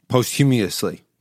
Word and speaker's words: posthumously: Is it posthumous? – Posthumously. – Posthumously. I don't know how to pronounce that. – It's not hummus. – It's definitely not hummus.